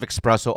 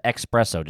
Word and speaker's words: espresso: Some sort of cold brew or four shots of espresso over ice. – I like that. – They also pronounce it espresso, just letting you know. – What do you say? – It's espresso. – There's no X.